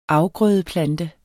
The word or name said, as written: afgrødeplante